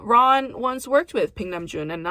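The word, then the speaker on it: Baek Nam-jun (백남준): Ron once worked with a very influential artist named Baek Nam-jun, whose name often gets butchered in the American lexicon as Nam-jun Paik. But that's not how it's pronounced. It's Baek Nam-jun.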